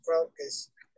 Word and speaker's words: Crocus: She used to pronounce my name Crocus. And I said, no, it's Crocus.